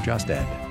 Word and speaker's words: Jostad: I always pronounce Drew Jostad as with a T, but it's not really it. It's a D. Drew Jostad.